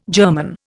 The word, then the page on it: German: Indian